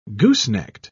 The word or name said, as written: goosenecked